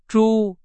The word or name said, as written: 猪